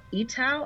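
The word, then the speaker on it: Eatough: So she's going to be doing part of the interviewing along with Russell Eatow. I don't want to mispronounce his name. It's E-A-T-O-U-G-H.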